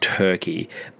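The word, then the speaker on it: Türkiye: I believe it's still pronounced Turkey, but it's spelt differently.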